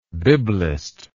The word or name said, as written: biblist